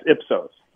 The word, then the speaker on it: Ipsos: Well, first of all, you got to tell us about, is it Ipsos? Am I pronouncing that correctly? – That's perfectly, yeah, that's perfectly fine. That's correct. Yeah, it's Ipsos.